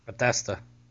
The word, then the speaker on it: Bethesda: How do you say it? – Bethesda. – Bethesda. There you go.